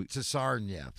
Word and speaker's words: Tsarnaev: So Tsarnaev.